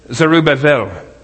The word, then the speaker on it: Zerubbabel: I was looking at, you know, people from Israel pronouncing this stuff, and like I say Zerubbabel, because I kind of maybe grew up with Barney Rubbable or something, I don't know. But I like Zerubbabel, and it's Zerubbabel.